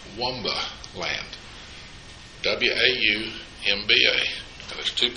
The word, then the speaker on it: Waumba: W-A-U-M-B-A is the way it's spelled over there. Wumba.